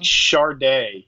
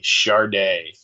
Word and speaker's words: Sade: I mean, that's like Charday, you know, S A D E, Charday.